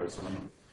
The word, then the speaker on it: Arzano: And we have an expert on this subject here at PI, Achille Arzani. If I pronounced it correctly, Arzano, Achille Arzano.